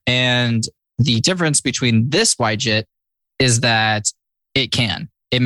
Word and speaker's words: YJIT: What did you say? – Yuh-Jit. You said it wrong.